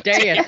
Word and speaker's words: Deus: It's Deus. It's Greek.